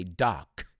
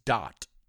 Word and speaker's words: dot: And I've heard some people mispronounce and say doc. It's not doc, it's dot, D-O-T, dot, dot com.